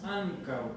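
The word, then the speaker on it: ankaŭ: La pronunciación es ancao.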